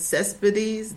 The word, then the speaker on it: Cespedes: And I'd like to give a special shout out to Vivian Cespedes. Did I say her name correctly? – Yes, you did.